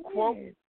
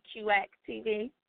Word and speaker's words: QX: Also has a blog called Quote. Did I pronounce it correctly? – Okay. So everybody calls it Quote, so I just let it go, but it's actually QX TV.